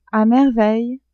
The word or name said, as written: a merveille